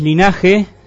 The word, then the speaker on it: Linaxe: ¿Cómo nace esta idea, este proyecto de Linax? ¿Es o Linaxe? Claro, en realidad es Linaje. Se escribe Linaxe con X, es un nombre vasco.